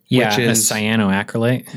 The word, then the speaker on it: cyanoacrylate: Cyanoacrylate.